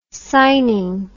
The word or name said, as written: signing